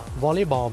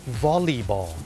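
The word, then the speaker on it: volleyball: วอลเลย์บอลไปแล้วเนี่ยคนไทยเนี่ยเมื่อพูดถึงกีฬาวอลเลย์บอลเนี่ยออกเสียงว่าวอลเลย์ถูกไหมครับวอลเลย์บอลแต่จริงๆแล้วนะครับเจ้าของภาษาเนี่ยไม่ได้ออกเสียงว่าวอลเลย์บอลนะครับเขาออกเสียงว่าวอลลีบอล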